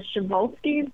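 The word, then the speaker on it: Przewalski: you have the really tough horse name to pronounce, which is the Shevolsky, but you would have never thought that's how you pronounce it.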